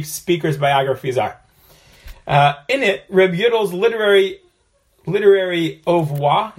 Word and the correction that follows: oeuvre: is that how you pronounce that? O-E-U-V-R-E? I don't know how to pronounce that. Is subdivided into three categories. There are three categories. What is it? – It's Oeuvre.